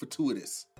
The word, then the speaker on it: fatuous: Asking for advice and not taking it can make a person look fatuous. Fatuous means stubborn. – So you said it wrong again. – Fascist? – The T is like a CH.